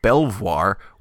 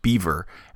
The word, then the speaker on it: Belvoir: I talked about something that I called the Belvoir witchcraft case, and I said that because it is spelled B-E-L-V-O-I-R. But Steve Dempsey informs me that it is in fact pronounced Beaver, and I for one believe him.